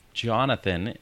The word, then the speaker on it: Jonathan: Jonathan is my first name, for those that don't know that.